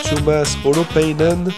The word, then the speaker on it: Tuomas Holopainen: C'était Into the West de Tuomas Holopainen, c'est mieux prononcé comme ça, sur Fréquence BD, une chanson sur la jeunesse de Picsou.